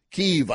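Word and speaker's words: Kyiv: We still have 40 miles of equipment stalled out, 40 miles north of Kiev, I should say. That's a Ukrainian pronunciation.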